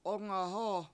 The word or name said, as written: 7ungahOO